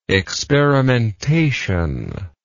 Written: experimentator

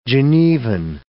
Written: Genevan

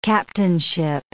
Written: captainship

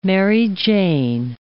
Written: Mary Jane

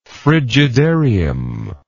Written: frigidarium

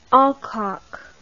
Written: alcock

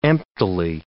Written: emptily

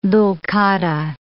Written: Dhu'l-Qadah

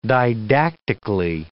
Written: didactically

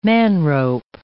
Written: manrope

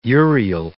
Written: Uriel

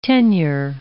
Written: tenure

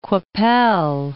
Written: Qu'Appelle

